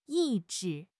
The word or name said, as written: 意志